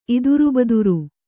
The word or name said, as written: ಇದುರುಬದುರು